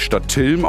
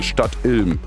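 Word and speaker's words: Stadtilm: Der Kollege hat eben gesagt, es wird nicht Stadt Ilm ausgesprochen, sondern Stadt Ilm.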